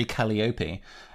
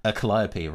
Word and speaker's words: Calliope: And the other thing, because I was speaking about VTubers recently, is the song Off With Their Heads by Maury Calliope. Calliope, rather, pronouncing her name correctly,